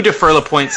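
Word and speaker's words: Dufour-Lapointe: The Defer LaPointe sisters, am I saying that correctly? I'm going to get it right once, aren't I? – Yes, Ben. The two Defer LaPointe sisters make up three that are related to each other.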